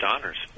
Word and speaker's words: Honors: It's just Honors. – Okay, because in your bio it's got... – There's two H's, but the first H is silent, kind of a Honors.